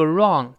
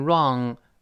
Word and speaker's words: wrong: 注意这个 wrong w 不发音，r 勾舌头，最后舌根音 wrong wrong 这么一个声音啊。